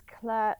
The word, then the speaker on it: Clair: So it's Clair Lythgoe, C L A I R, no E, L Y T H G O E.